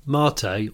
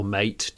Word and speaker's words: Mate: It's really interesting because Mate, or Mate, depending on how you say his name, he's a lovely, lovely guy. He's always Like Pate, isn't it? Or is it? – Oh, is it Mate? Oh, there's three versions of his name already.